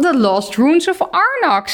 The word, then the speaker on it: The Lost Ruins of Arnak: Eh, dan The Lost Ruins of Arnak.